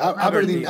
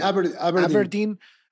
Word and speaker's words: Aberdeen: Aberdeen, Aberdeen,